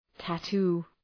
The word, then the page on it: tattoo: tæ’tu: